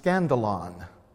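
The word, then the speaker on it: skandalon: scandalon.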